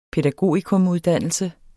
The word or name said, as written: pædagogikumuddannelse